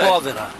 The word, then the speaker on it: povera: But the expression arte, is it povera or povera? How do you say? – Povera. – Povera. – In Italian, it's povera.